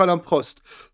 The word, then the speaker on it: Alain Prost: Oui, ça se prononce Alain Prost.